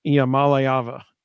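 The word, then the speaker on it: Iamaleava: So the emphasis was on the ma and the lay, according to the UT Sports pronunciation guide. So just like Reed just said, it's Iamaliava.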